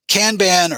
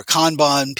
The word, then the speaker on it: Kanban: Kanban or Kanban, people pronounce it different ways.